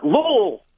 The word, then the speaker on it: Louisville: Much love to Louisville, or as they pronounce it out there, Louisville.